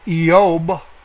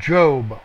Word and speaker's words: Iob: We are in the book of Iob. That's how it's pronounced properly. But in English, they have Job with a J.